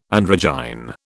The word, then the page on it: androgyne: British English